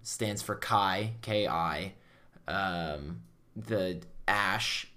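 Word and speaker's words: X Æ: No, it's XAE, which is pronounced Ash, and then something X12 or whatever.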